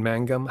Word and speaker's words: Mangum: But there's something really fun about seeing Jonathan Mangum. How do you pronounce his last name? – That was right.